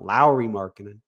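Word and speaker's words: Lauri Markkanen: We said that wrong last podcast. Someone shouted it out. Lowry Markkanen.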